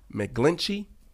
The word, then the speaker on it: McGlinchey: Is Mike McGlinchey.